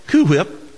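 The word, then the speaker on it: Cool Whip: Or in some, most of y'all's cases, Coo Whip, which you do not pronounce the L. Angela gets mad at me for that, but you do not pronounce the L. It's Coo Whip.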